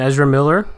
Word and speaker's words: Ezra Miller: All of a sudden, the internet is completely changing their tune on Ezra Miller. That's how you pronounce his name. If not, sorry, I'm going to call you Ezra. Urza, whatever.